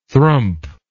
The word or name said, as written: thrump